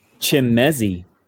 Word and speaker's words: Chimezie: I just figured out how to say his first name, and it's cool. – It is Chimezie Meadow.